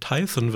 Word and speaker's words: Tyson: Tyson wird ausgesprochen, wie Mike Tyson seinen Namen selber sagt.